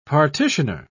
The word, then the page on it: partitioner: British English